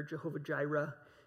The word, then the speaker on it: Jehovah Jireh: Some versions put a J there. Jehovah Jireh is another way that's pronounced.